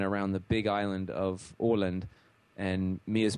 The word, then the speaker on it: Åland: Okay, and it looks to me like spelled A-L-A-N-D, and you pronounce it O-L-A-N-D. – It's Åland. Yeah, it's actually, if you look closely, the A has a circle over the top of it, and that's a letter that they have in the Swedish alphabet that we don't have in English, which is pronounced Å, kind of like O-A together. So it's actually pronounced Åland, which is how you say it.